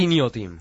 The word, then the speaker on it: Inhotim: Inhotim. Inhotim, no sé, la H está ahí entre la I, entre la N y la O, no sé cómo se pronuncia. – Inhotim. – Inhotim.